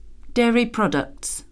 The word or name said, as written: dairy products